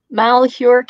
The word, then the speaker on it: Malheur: Malheur County is how you say that.